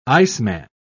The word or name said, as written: iceman